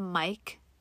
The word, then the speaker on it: Myq: His name is Mike Kaplan. Mike is spelled M-Y-Q, which I love.